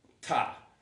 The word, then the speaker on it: Ptah: And one of the gods that it was connected to after some time was the Egyptian god known as Ptah. It's the next line of your notes. And I'll spell this out for you. P-T-A-H. P-T-A-H. The P is silent, right?